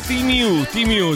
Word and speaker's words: Temu: Temu è Timu, si pronuncia così.